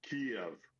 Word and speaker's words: Kyiv: It's not a single-syllable city. It is Kyiv.